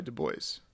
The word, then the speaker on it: Du Bois: I was a little bit confused about how you pronounce that. I've heard Du Bois, but I believe Du Bois is correct. – Right, like the actual French pronunciation, I can't even pronunciation. French pronunciation would be Du Bois, but he went by Du Bois.